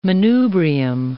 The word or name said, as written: manubrium